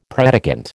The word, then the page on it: predicant: American English